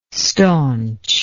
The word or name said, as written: staunch